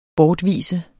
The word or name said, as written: bortvise